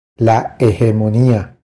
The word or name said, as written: La hegemonia